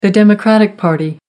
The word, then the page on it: democratic party: American English